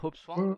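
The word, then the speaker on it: Hope S. Wang: Et la même année, je le cite en dernier, c'est pas pour rien, c'est Sherlock 13, donc de Hopswang avec euh, toi, euh, tu corrigeras peut-être la, prononciation, c'est bien ça, Hopswang Oui, oui ça va.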